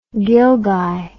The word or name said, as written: ghilgai